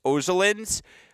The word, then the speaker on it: Ozolins: And, like, Carlos Ozolins, by the way, that's how you say it, Chris, not that you're ever going to say it right, he's the real deal.